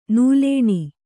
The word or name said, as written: ನೂಲೇಣಿ